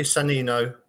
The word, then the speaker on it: Sannino: So that's Santino. It's bad pronunciation, but it's... – it's Giuseppe Sannino.